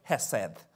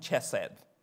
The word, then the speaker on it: hesed: hesed. Some pronounce it chesed, hesed.